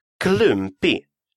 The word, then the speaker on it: klumpig: «Клюмпи» – «неуклюжая». Клюмпи. Обратите внимание, что буква «г» после буквы «и» не читается.